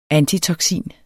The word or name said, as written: antitoksin